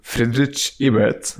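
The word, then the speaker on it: Friedrich Ebert: انت المفروض مدير برنامج البيئه والطاقه والمناخ في مؤسسه فريدريش ايبرت. – فريدريش ايبرت.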